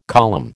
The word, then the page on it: column: American English